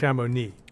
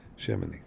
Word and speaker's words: Chamonix: Chamonix. – Like it begins with sham. – All right. – Okay. Yeah. – Knee. Oh. Like your knee. – Yeah. Chamonix.